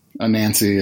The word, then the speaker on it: Anansi: Is it a Nazi or a Nancy? – Anansi is how I've, A-N-A-N-S-I, I think. Anansi is all, how I've always pronounced it because of Gaiman.